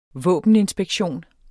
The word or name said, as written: våbeninspektion